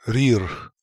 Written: Rirkh

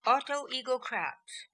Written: Autoegocrat

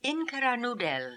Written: Inkiranoodel

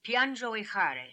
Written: Piandjoehary

